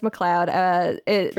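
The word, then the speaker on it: MacLeod: Last week, I pronounced his name McLeod Andrews, and then immediately after recording, I was like, oh, his name is fucking McLeod. It's McLeod. It's McLeod Andrews. It's pronounced McLeod.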